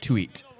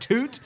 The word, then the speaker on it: Tuite: I never knew how it was pronounced, but it's like T-U-I-T-E. So you think it's like tweet. – Yeah, your instincts is tweet. – I hear it on podcasts. It's like, yeah, so Jerry Toot. And it's like, it's pronounced Toot. His surname is Toot.